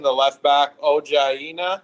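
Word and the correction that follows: Ola Aina: Is that how you say it, Alex? – It's actually Olaena.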